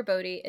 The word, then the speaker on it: Boddy: So it's at Heather Bodie. It's just my name. Very, very easy. But Bodie is spelled B-O-D-D-Y. So it looks like body, but it has two D's.